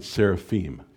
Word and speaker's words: seraphim: Probably we should pronounce it seraphim, because I am is that sound in Hebrew.